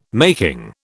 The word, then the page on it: making: British English